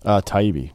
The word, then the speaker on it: Taibbi: How do we pronounce that, by the way? How have we been pronouncing that? – Taibbi. – Sure. – Is how you say it.